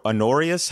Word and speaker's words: Honorius: is it Honor- honorius how do you pronounce the emperor I always pronounce it Honorius. – Yeah.